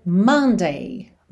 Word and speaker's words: Monday: Se escreve com O-M-O-N-D-A-Y, mas esse O não vai ter esse som Monday, tá? E sim Monday, Monday. O mais próximo, se a gente pensar em português, é Monday.